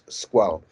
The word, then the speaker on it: Squale: Or a Squale, rather, as I should know, because of course, whilst Squale is the Italian word for shark, it is of course a Swiss company, and they pronounce it the French way, which is Squale.